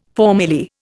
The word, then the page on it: formerly: Indian